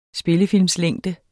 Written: spillefilmslængde

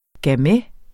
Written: GAMAY

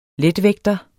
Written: letvægter

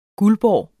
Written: guldborg

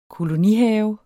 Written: kolonihave

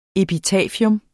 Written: epitafium

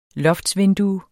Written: loftsvindue

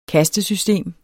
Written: kastesystem

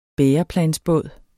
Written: bæreplansbåd

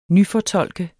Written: nyfortolke

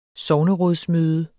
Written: sognerådsmøde

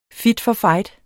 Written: for

